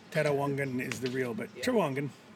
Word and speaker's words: Trawangan: Trawangan.